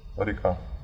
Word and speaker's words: река: Река. – Река. – Second stress syllable.